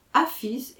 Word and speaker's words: Hafiz: Hafiz est la prononciation persane du mot arabe « hafed », gardien, conservateur.